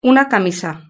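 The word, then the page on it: una camisa: European Spanish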